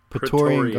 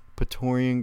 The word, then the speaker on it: Praetorian: Praetorian guards. – Yeah. – Praetorian guards.